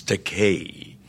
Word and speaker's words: Takei: but it's the vowels in Japanese are pronounced like the Mediterranean languages, Italian, Spanish. It's Takei, E-I. However, there is a Japanese word pronounced Takai with an A, T-A-K-A-I, and that translates into English as expensive.